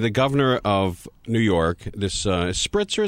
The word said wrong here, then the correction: Spitzer: His name is Spitzer. – Spitzer, all right, there you go. – Think Spitball, Spitzer.